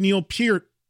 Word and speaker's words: Peart: I've interviewed Geddy and Alex countless times, both TV and radio, and even interviewed Neil Peart, and yes, that is how you say his last name properly.